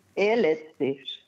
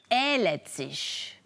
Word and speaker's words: ehletzisch: Können Sie das Wort richtig aussprechen? – Ehletzisch. – Ehletzisch. – Ja.